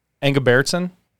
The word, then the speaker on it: Engebretson: E N G E B R E T S O N, Engebertson.